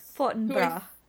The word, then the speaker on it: Fortinbras: Yeah, I think I was saying Fortinbras in my head. – Yeah, that's what I was saying in my head too. I was like, slightly French pronunciation. – Yeah. Yes. Fortinbras.